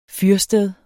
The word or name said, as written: fyrsted